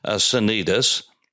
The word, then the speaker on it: Cnidus: And so we'll pronounce them with a soft sound, Cnidus.